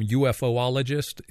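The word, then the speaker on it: ufologist: Is that how you pronounce that term, UFOologist? Is that how you say that? – That's correct, although the official term now is UAP, Unidentified Aerial Phenomena.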